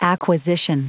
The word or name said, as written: acquisition